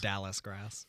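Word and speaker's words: dallisgrass: Is it dolly grass? – Dallas grass.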